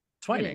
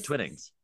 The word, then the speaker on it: Twining: Twining. – It is. Twining's T. – Or is it Twinnings? Doesn't matter. Moving on. – Well, it's one end, so it'd be a long I.